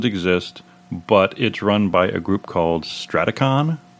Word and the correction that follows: Strategicon: I think, is the Strategicon. – Strategicon, thank you.